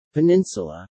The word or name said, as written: peninsula